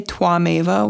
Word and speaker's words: Tvameva: It's spelled T V A M E V A. And the T V at the beginning, I believe, is pronounced more of a Twa, like it's a cross between a W and a V sound, Tvameva.